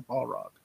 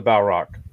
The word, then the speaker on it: Balrog: That's how you say it, the Balrog. – Yeah, the Balrog.